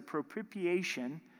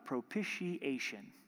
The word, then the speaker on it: propitiation: In verse 25, whom God set forth as a propitiation by his blood. That's a mouthful, propitiation.